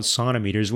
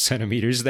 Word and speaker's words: centimeter: sonometers when they very well knew that you could pronounce it centimeters? And not only that, it is pronounced centimeters. That is just how you do it in English.